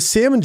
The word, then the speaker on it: salmon: The salmon joke got posted on, not the joke, but a reference of like when... It was on Hoodville. It was like when Shoddy pronounces the L in salmon.